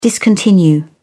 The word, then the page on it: discontinue: British English